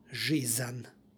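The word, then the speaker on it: žizan (жизнь): Sonant se u ovom primjeru nalazi na kraju reči, ispred njega stoji suglasnik, stoga se između njih radi lakšeg izgovora dodaje nepostojano a, stoga izgovor žizan.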